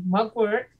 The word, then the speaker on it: mugwort: So your cleansing herbs, you have the mugwort.